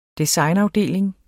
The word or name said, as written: designafdeling